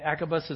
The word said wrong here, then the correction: abacus: is the little bead thing, right? Abacus. There we go. Can't even say it right.